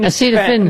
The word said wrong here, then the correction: acetaminophen: Acetaminophen, sir.